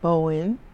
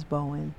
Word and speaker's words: Bowen: It's Iris Donita Bowen, and it's spelled I-R-I-S. Donita is D-O-N-I-T-A, and my last name is Bowen, B-O-W-E-N.